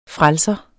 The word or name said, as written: frelser